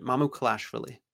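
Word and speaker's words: Mamukelashvili: Mamu um, Kalashvili is how you pronounce it. – Mamu Kalashvili.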